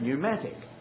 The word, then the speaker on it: pneumatic: It's like our letter P in pneumatic. I hope you don't say the word pneumatic. So we usually say it pneumatic. It's a silent P, and there are a number of words in our language like that.